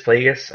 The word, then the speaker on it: Plagueis: Darth Plagueis? – Yeah, Plagueis.